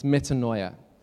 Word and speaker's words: metanoia: The word repent is the Greek word which John taught me how to pronounce this morning. It's metanoia.